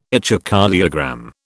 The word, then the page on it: echocardiogram: British English